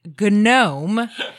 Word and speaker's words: GNOME: The- with a G, and I'm going to pronounce it the Croatian way, which is GNOME.